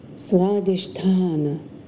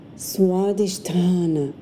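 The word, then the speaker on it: Swadhisthana (स्वाधिष्ठान): The second, or sacral chakra, in Sanskrit is pronounced Swadhisthana, Swadhisthana.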